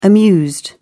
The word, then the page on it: amused: British English